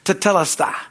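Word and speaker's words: tetelestai: but it is tetelesta, okay, T-E-T, all right, E-L, S-T-A-I.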